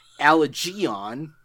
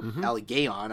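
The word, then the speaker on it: Allegaeon: Looks like the guys over in, and let me make sure I'm pronouncing this right, because I'm pretty sure I'm not, Allegeon, or Allegeon, I'm not sure which. – Soft G, all right.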